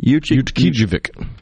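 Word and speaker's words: Utqiaġvik: Ukijevik. – Yeah. Well, the sun is set there. And say it for me. – Ukijevik.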